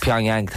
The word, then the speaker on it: Pyongyang: Pyongyang, thank you very much.